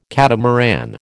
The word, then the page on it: catamaran: American English